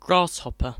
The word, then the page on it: grasshopper: British English